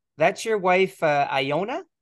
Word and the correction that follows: Ilona: Is her name Iona? How do you pronounce it? – Call it Ilona. Ilona. – Ilona. – Ilona. Yeah.